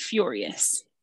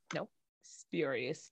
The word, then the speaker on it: spurious: I thought you said furious. – Nope. Spurious. Spelled the same, but with an S-P instead of an F, like Frank.